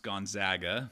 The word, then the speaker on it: Gonzaga: Pronounced Gonzaga for anybody.